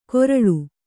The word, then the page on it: ಕೊರಳು: Kannada